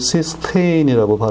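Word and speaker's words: cysteine: 근데 이제 시스틴의 구조를 그 설명하기 위해서 일단 시스테인부터 말씀드리면 근데, 그 스페링이 이제 그 발음만 가지고잘 구별이 안 될 텐데 C-Y-S-T-E-I-N-E e 이렇게 쓰면 이게 제가 지금 시스테인이라고 발음하는 거고요. 근데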